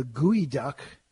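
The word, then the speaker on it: geoduck: And the gooey duck, spelled G-E-O-D-U-C-K, I learned how to pronounce that today.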